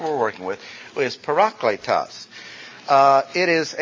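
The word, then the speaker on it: parakletos (παράκλητος): The word is not pronounced paraclete. It's parakletos. P-A-R-A-K-L-E-T-O-S. A-T-O-S. Parakletos. I'll put it two-part. Letos. Parakletos. A lot of times words in the original language gets anglicized and they come across with parakletos.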